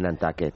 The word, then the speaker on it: Nantucket: ¿Pero esto fue cuando fuiste a Nantucket? – Cuando fui a Nantucket, se dice Nantucket.